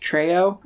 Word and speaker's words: Treo: But the phone is a, I think it's called, pronounced Treo. It's T-R-E-O.